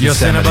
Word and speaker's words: Yosemite: You said to me? – You didn't say it right. You didn't say it right, but we know what you meant. Yes, Yosemite.